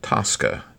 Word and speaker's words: TSCA: the Toxic Substances Control Act, which goes under the acronym of TSCA and is pronounced Tosca, just like the opera of the same name written by the Italian composer Puccini, was restructured under a bipartisan agreement in Congress during the Obama administration.